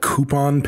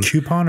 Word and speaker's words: Coupon: They were outside of a town called Coupon, Pennsylvania. Mm. Which is apparently a real town. – Coupon or Coupon? – It's spelled like A coupon. – Coupon. Oh. C-O-U-P-O-N.